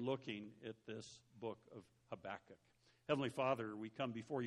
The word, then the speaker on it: Habakkuk: Habakkuk, Habakkuk, yeah, it's got lots of extra K's in there, I think. But Habakkuk is how I will be pronouncing it.